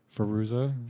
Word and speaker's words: Fairuza: Fairuza.